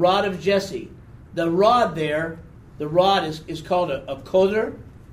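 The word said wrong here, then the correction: Hoter (חוטר): I pronounced that wrong. Try it again. Hoter. Hoter. C-H-O-T-E-R. C-H-O-T-E-R. The pronunciation is Hoter. Okay? And the branch is Netzer. Netzer. N-E-T-Z-E-R, N-E-T-Z-E-R, Neser.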